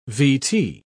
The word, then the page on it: vt: British English